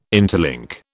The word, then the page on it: interlink: British English